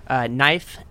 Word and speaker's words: Knife: Knife and Fox. – That's a silent K.